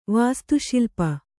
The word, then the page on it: ವಾಸ್ತುಶಿಲ್ಪಿ: vāstu śilpi